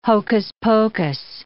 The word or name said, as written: hocus-pocus